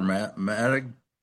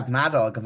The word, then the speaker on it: Madog: So the Maddox Center, how do you pronounce that? Madog or Maddog? – You've got it. Yeah, Madog, the Maddox Center for Welsh Studies.